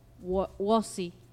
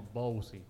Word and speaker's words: Wossi: La primera U. – Claro. – Wossi. – Wossi. – Porque a veces también se pronuncia como Bowsi. – Claro, y se confundía Bowsi. La mayoría, ¿no? Y es Wossi.